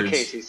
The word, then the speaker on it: Lucchesi: Oh, Lucchesi, sorry. I always say that wrong.